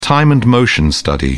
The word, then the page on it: time-and-motion study: British English